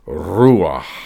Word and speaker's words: ruach: Paul understands Spirit as mainly the same as the Old Testament use of the Hebrew word ruach. I think that's the way you would pronounce it, ruach.